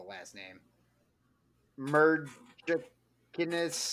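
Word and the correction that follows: Merzlikins: I think I can give it to you. – Elvis, right? – Elvis. Okay, Elvis yeah, Merzlikens.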